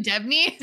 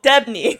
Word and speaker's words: Debney: John Debney? – Doobie. Oh, Debney.